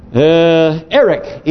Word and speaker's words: Erech: For instance, there was an ancient city in Sumer by the name of Erech, E-R-E-C, as we pronounce it phonetically, that can be identified today as Warka.